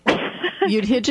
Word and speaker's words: Yudhijit: I always want to do like the Hebrew pronunciation, but it's Yudhijit.